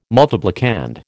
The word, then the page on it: multiplicand: American English